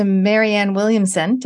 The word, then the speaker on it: Marianne Williamson: Okay, so if anyone's got connections to Marianne Williamson, did I say it right? – Yes.